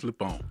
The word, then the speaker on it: Lupin: Lupe. – Lupin. It's pronounced Lupin. – Lupin. – Oh, excuse me, Mr. Cowboy. – It's French.